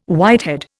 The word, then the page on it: whitehead: Indian